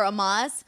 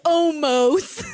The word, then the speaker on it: Omos: He was like, it's going to be me and Omos. And I'm like, okay, so nobody like cared to tell Brock, hey, dude, like that's we don't pronounce the O or whatever. It's pronounced like an A, whatever.